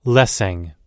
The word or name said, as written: lessing